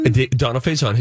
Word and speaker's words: Donald Faison: He and Donald Faison, is that how you say his name? – Donald Faison, his fellow Scrubs co-star.